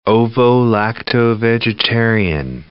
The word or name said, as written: ovo-lacto vegetarian